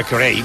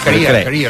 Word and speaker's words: Carey: Com li dieu? – Jo, Maria Carey, no? Maria Carey, no es diu? Maria Carey, no? Com es diu? – Carey. – Carey. – Bueno, descobrim-ho, ho hauríem de pronunciar així. – Maria Carey. – Repetim? – Com som? – Maria Carey. – Ara bé, Maria Carey. – Maria Carey. – No, Carey.